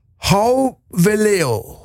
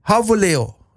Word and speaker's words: Gouweleeuw: Das ist ein Niederländer, Jeffrey Hauweleo wird er ausgesprochen. Geschrieben wird er G-O-U-W-E-L-E-E-U-W. Und er bereitet mir seit Jahren Probleme. Ja, und er wird gesprochen Hauweleo.